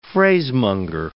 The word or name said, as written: phrasemonger